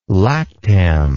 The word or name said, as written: lactam